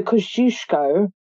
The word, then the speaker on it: Kościuszko: If I say this, I think I'm going to say it wrong. But the Kościuszko, as Americans say it, you have many of his statues and bridges and roads and whatnot, because he's also an American hero.